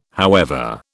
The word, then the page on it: however: British English